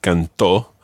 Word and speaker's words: cantó: Now, what's the thing that you've got to remember about the whole business of cantó and canté in the preterite tense? – You've got to put the stress on the last syllable so that they're different from the present tense.